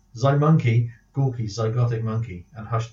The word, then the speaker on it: Mynci: And then monkey is Mynci. Monkey.